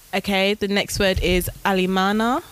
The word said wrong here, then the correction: Alemana: which is Alemana.